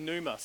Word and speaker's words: pneuma: Now, in the New Testament, as we heard, is Greek, and, and it's mainly pneuma, spelt with a P.